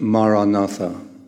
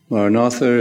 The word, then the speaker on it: Maranatha: If you choose this word, say it as four syllables. Ma-ra-na-tha. Ma-ra-na-tha.